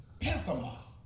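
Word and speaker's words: pathema (πάθημα): Suffer in the Greek vernacular is pathema. Pathema. It's spelled P-A-T-H-E-M-A. Pathema.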